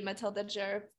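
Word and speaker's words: Matilda Djerf: Next is the Matilda Jerf haircut. I don't know if I just butchered her last name wrong, but. – No, that's how you say it.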